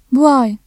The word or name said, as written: bu ay